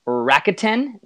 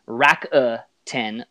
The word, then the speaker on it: Rakuten: Now, I was told by my coworker that in Japan, Japanese people a lot of times do not pronounce vowels. And so the reason why in Japan people call it the company Rakuten, they don't really pronounce that U, Rakuten, like Americans do.